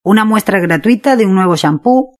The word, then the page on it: una muestra gratuita de un nuevo champu: Spain Spanish